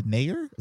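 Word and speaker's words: Naeher: Also, they had to replace Hope Solo too with Alyssa Nayer. Is that how you pronounce her last name? Yeah, and so they demolished Thailand today,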